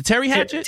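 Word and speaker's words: Teri Hatcher: When Paris was played by Terry Hatchett. Did I say her name right? It was a Terry Hatcher. – Terry Hatcher. Yeah.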